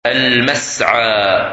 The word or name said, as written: Masa